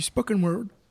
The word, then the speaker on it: spoken word: On continue tout de suite avec du spoken word. Je le prononce bien, Yannick ? – Ouais, ouais, c'est ça.